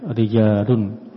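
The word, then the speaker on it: rijalun (رِجَالٌ): Ra maftuhah wa jim madhumatun. Rajulun. Bil jam'i, tapi ketika dijamak, jamak taksir, dibacanya rijalun, ra-nya di kasrah, jim-nya di fathah.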